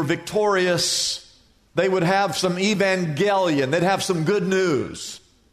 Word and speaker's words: evangelion: Now, the word good news in the Greek, you can write this down in your notes, is evangelion, is how you pronounce that, which is where we get our English word evangelism.